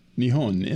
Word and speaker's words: Nihon: Nihon, N-I-H-O-N. Or Nippon is sort of the more familiar. – The Japanese name for Japan can be pronounced either Nihon or Nippon.